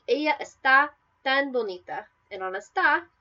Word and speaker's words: está: For example, esta versus está.